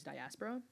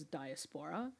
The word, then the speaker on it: diaspora: And every time said it as diaspora. And she wasn't Chinese. And so it wasn't like this was how the Chinese diaspora pronounces diaspora. This was just like some white chick who was pronouncing diaspora wrong. – Putting the emphasis on the spore.